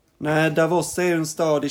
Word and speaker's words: Davos: Davos tror jag det uttalas. Jaha, Davos. Ursäkta.